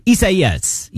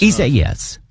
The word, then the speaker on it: Isaias: Hurricane Isaias, I think, is how you properly pronounce that. Let's check with Metro News meteorologist Brian Hughes. Am I saying it right? Close enough? – Yes. Yeah, close enough. Isaias. Yes. Right. That is correct. Isaias. Yeah.